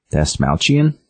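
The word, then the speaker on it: Dastmalchian: Dasmalchian? – There you go.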